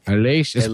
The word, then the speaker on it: Aleix: Aleix.